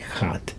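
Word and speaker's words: Khat: And if you're wondering how to spell that, it's no, it's K-H-A-T, Chat.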